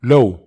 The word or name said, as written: low